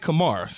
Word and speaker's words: Kamara: Given the fact that you have Michael Thomas, you have Emmanuel Sanders, Kamara. If I said it right. – Kamara, Alvin Kamara.